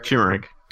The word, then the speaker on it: turmeric: So I was going to be like, oh, it's the color of how do you pronounce that? Turmeric? – Turmeric. – Turmeric, right.